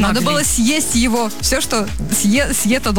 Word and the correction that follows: съедено: должно быть съето. Ну, в смысле, съедобное. Должно быть съедено. Я знаю, как правильно произносить это слово, а то солнечное имя тут закрывало за эфиром.